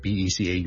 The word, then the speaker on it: because: 上一集萧叔叔同大家讲咗 since 同埋 because 呢两个字，咁啊不如顺便讲讲 because 咧，because 呢个字个发音，好多时候咧，我哋系唔会读成好完整嘅，我哋正确啊，because 咧，because 系 b 啦，第一个 vowel 系 a 啦，第二个 vowel 系 o，跟住后面系一个 z 音，because，但系咧。